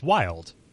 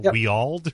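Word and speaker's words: Wyald: I was like, oh, it's Wild. Like, literally, that's just how it's said. I was, like, saying Weald or whatever.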